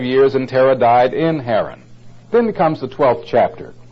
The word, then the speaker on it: Haran: They named it after his brother who had died, Haran, H-A-R-A-N. In the New Testament, it's spelled with a C-H because it in Hebrew would be pronounced like Haran, something like that. My Hebrew is not the best, but it's almost like you're hocking up a loogie or something, you know.